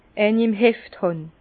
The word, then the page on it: eini im Heft hàn: Bas-Rhin; Herrlisheim